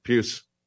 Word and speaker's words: Pius: Okay, number three on my list is Piyu Suter. Piyu Suter, who was in Detroit. That's all you say his name, right? – Yeah, Pius. – Pius? Okay, Pius Suter.